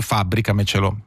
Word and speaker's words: fabbricamecelo: Uno ti chiede ti fabbrico il gabbiotto degli strumenti nel giardino e tu gli rispondi fabbricamecelo. che tra l'altro è una parola con accento sulla sest'ultima sillaba, quindi quadrisdrucciole, credo che sia l'unica nella lingua italiana.